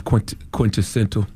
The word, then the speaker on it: quintessential: So dropping the clues bombs for the quintessential gentleman magazine, and I just finally learned how to pronounce quintessential. – Properly. You still don't have it right. – Oh, see, I'm still not doing it right. See, it's just like essential, but with quinta in front of it.